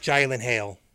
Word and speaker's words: Gyllenhaal: It's pronounced Gyllenhaal. So Gyllenhaal. Gyllenhaal. – Get it right.